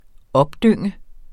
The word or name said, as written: opdynge